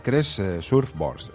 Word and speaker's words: Crest Surfboards: Es la fundadora de Crest Surfboards.